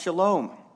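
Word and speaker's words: shalom (שלום): It's pronounced shalom.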